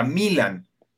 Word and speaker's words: Milan: Bueno, pues es que fue fundado por, por ingleses, por eso, se le, por eso se pronuncia Milan en inglés.